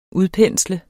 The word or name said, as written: udpensle